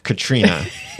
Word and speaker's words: Catriona: It's spelled different. It's like Catriona. But she put in here that it's pronounced Katrina. Actually, here's the way she actually writes it. She says, by the way, it's Katrina, not Katrina. Or it's Catriona, Katrina, not Catriona.